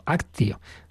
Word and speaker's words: actio: Entonces él analizaba esa palabra participación en la tradición de la Iglesia y decía que realmente hacía alusión a una acción principal, en latín actio, actio, que solemos pronunciar en latín la T como, como C una acción central, un acción central en la que deben participar todos los miembros de la comunidad.